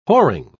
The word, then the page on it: whoring: British English